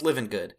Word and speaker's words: Livengood: Did I say Livin' Wood? It's Livin' Good. It's spelled Livengood.